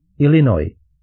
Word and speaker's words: Illinois: To slovo Illinois se vyslovuje bez S na konci, tedy Illinois, řekl tatínek.